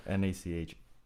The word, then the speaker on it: NACHA: NACHA. – NACHA. Nachos? – Not, no. not nachos.